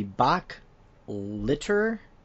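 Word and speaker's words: Bok Litur: Then for Escalation Protocol, we have the Bach Litter Hunger of Zol. I think I said that right. It sounds like litter, like cat litter, like Bach litter.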